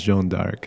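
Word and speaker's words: Jeanne d'Arc: Joan of Arc in French is pronounced Joan d'Arc, so...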